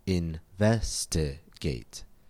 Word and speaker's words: investigate: Investigate has four syllables. Investigate. Count back two syllables from the suffix A-T-E, and we find the stress on ve. Investigate. Anytime you see a word with three or more syllables which ends in A-T-E, and you're not sure where the stress is, just follow the rule.